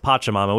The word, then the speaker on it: Pachamama: So I thought, you know, there's Pachelbel's cannon, a pack of pachyderms, Pachamama, but I'm incorrect. It's pronounced Pachamama, which is also harder when you're doing pronunciations in foreign languages.